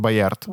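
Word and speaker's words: Bayard: Первопроходцем в жанре как раз э, снимать мертвых считается тот чувак, про которого мы уже сегодня говорили, и Полит Байер. – Он Боярд. Боярд, который снял свой автопортрет утопленника.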